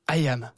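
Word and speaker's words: IM: Il faut le prononcer ça. I am, comme je suis en anglais.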